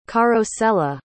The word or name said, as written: carosella